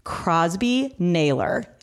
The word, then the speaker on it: Crosby Naylor: Today's podcast, I have joining us Crosby Naylor.